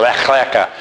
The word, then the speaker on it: Lech Lecha: They're all reading, and it's known as, and I'll not say it right, but the C-H is a hard K. It's, you make a sound like you're trying to hawk out your partial plate. It's like lech lecha.